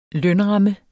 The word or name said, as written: lønramme